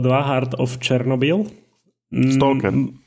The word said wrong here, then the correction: Stalker: Stalker, pardon. Prepačte všetci, čo nenávidíte moju výslovnosť.